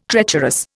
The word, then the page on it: treacherous: Indian